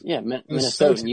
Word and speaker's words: Minnesotans: Minnesotans. You added an I in there for some reason.